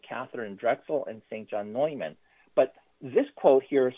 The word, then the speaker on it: Neumann: Those of us in Philadelphia are very aware that the St. Newman that we have is pronounced St. John Neumann, and he was the third or the fourth bishop here of Philadelphia.